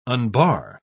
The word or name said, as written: unbar